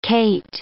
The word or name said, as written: cate